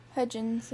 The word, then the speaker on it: Hudgens: How do you say it? Hudgens? – Hudgens, yeah.